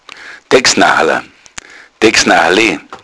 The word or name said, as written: dexnachala